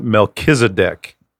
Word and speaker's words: Melchizedek: How do you pronounce this guy's name? I've always pronounced it Melchizedek.